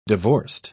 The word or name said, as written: divorced